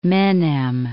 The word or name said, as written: Menem